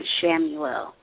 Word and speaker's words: Chamuel: But to spell Archangel Chamel, it's Chamuel. – Oh, okay. People pronounce it different. I would pronounce it Chamuel.